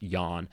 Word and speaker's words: Jahn: So another example is this guy, Otto Jahn, spelt like English John, but presumably pronounced Jahn, who died 1997.